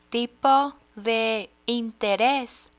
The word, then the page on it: tipo de interes: spanish